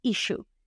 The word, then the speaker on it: issue: Y su e se escribe, pero a la hora de pronunciar esa doble S se convierte en sha, sha, issue, issue, issue, ¿ok?